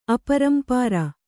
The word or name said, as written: ಅಪರಂಪಾರ